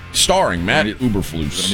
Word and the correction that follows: Eberflus: Nobody Lena said it's Eberflus.